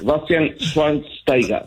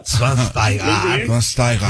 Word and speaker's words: Schweinsteiger: Después de 37 años de vida, ya sé cómo se pronuncia. Bastian Schweinsteiger. – Schweinsteiger. Sí, muy bien.